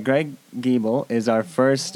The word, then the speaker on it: Giebel: His name is Greg Giebel. – We just learned how to pronounce it.